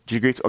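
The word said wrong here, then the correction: Dijkstra: Okay, someone pronounce that for me. His last name, Edward Jigriska. What's his last name? Jigstra, there we go.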